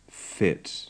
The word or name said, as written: fit